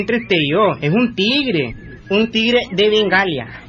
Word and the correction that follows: Bengala: En primer lugar, no se dice Bengalia. – ¿Y cómo se dice? Ah? – Se dice Bengala.